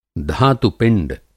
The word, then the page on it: धातु-पिंड: Hindi